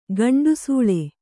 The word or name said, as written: ಗಂಡುಸೂಳೆ